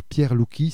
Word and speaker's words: Pierre Louki: Alors Pierre Louki, ça s'écrit L-O-U-K-I,